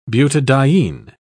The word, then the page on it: butadiene: British English